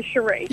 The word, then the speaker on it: Shiree: Cherie.